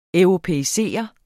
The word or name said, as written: europæisere